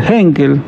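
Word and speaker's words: Heinkel: También hablar del Henkel, si lo podemos pronunciar así, acá me dicen sí, más o menos.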